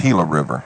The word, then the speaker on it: Gila River: It runs just east of, I'm looking at, is it a Gila River? – Yeah, it's pronounced Gila River. – Gila River, yeah, like Gila Monster.